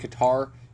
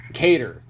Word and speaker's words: Kattar: Up next on the main card, we've got Calvin Kattar. I believe that's how it's pronounced. Calvin Kattar. – Cater.